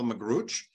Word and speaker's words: Magrutsche: My name is Michael Magruch.